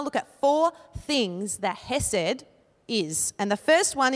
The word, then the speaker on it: chesed: which is chesed. I'm not saying it right. Chesed. Chesed. It's got that K in there.